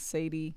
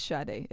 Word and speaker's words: Sade: The coolest name, the coolest thing about Sade is the way it's pronounced versus the way it's spelled. – Mm-hmm. I've gotten Sade, mm-hmm. all kinds of things. Sadie, yeah, but it's Sade, Sade.